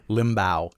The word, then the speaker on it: Limbaugh: Limbaugh is how he says it. – Limbaugh. Awesome. Like humbow.